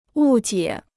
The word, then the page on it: 误解: wù jiě